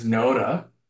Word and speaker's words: Noda: Now, like, I just say my name is Noda. That rhymes with Yoda, and my last name is Hashimoto, like the thyroid disease.